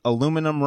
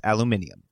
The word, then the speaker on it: aluminium: You've been pronouncing aluminum wrong this entire time. Look it up. It's aluminium.